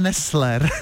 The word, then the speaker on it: Nessler: Jo, tady je potřeba vyslovovat precizně to L, aby nedošlo k záměně. A právě tenhle Nessler se rozhodl, že vymyslí Trvalou, ale potřeboval oběť.